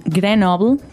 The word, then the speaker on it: Grenoble: It's just P-E-P apostrophe S, Peps. And these guys, they're actually from Grenoble.